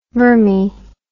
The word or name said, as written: vermi